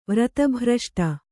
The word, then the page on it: ವ್ರತಭ್ರಷ್ಟೆ: vrata bhraṣṭe